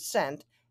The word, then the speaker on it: scent: How about English makes no sense when we have to wonder why there is a C in the word scent, S-C-E-N-T? What's the point? It's still pronounced like the word S-E-N-T. I sent the letter, the past tense of send. Or C-E-N-T, cent, like in 1 cent and 1/100th of a U.S.